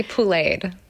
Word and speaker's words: pouléed: So it's poulet is the word for chicken in French. It's spelled P-O-U-L-E-T. So it's like to be pouléed. I think I spelled it, just added an E-D at the end, but it's yes. Sort of essential. Like, phonetically, it would be like P-O-O-L-A-Y-E-D.